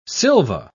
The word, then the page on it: silva: British English